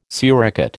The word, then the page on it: suricate: American English